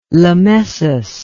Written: lemessus

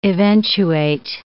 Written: eventuate

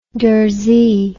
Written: darzi

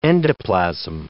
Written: endoplasm

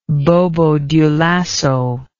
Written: Bobo-Dioulasso

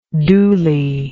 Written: dooly

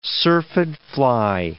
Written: syrphid fly

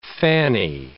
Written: fanny

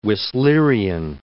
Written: Whistlerian

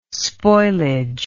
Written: spoilage

